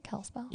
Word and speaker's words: Kalispell: Yeah, it also provides energy for, like, the towns right there, like Whitefish and Kalispell. Is that how you say it? Kalispell? – Yeah.